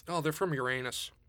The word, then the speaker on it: Uranus: It's like, well, it's pronounced, as Neil deGrasse Tyson said, once you're after the age of 12, it's Uranus.